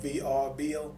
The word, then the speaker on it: Vrbo: And Now, when I was trying to find your place, or when I was trying to find a place, I was on Vrbo. I guess I'm pronouncing it right. VRBO. – Mm-hmm. Oh, yeah. VRBO.